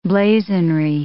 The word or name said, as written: blazonry